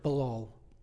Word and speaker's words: balal: Don't worry, I don't like saying Hebrew words, but there's a reason for it, balal. It rhymes with babal. That's Babel. There's more to it than that, though, because the Babylonian god Bel was also worshipped by the Canaanites as the Canaanite god Baal.